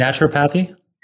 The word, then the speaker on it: naturopathy: And it actually touches some of the realms of machine learning and even naturopathy. I don't know if I'm pronouncing it well, but it's naturopathy. – Yes, that's right.